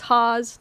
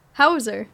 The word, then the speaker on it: Hause: Technically, as my whole family's been saying it my whole life, it's Hause, like cause. – Okay. – But I did go to Germany recently, like, I guess 2 years ago now. And I said, how do you pronounce this? Because it's a German last name. And they're like, Hauser.